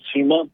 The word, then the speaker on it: CIMA: Solo por comprensión de nosotros, dice en el boletín de la Cámara de Comercio en Internet, se inició C-MA empresarial, pero ¿se pronuncia CIMA o cómo es la sigla? – Sí, tal cual, CIMA